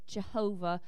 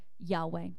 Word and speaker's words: Yahweh: So he built an altar and named it Jehovah, or uh, that's our pronunciation, but in the uh, as we know a little bit more and better Yahweh.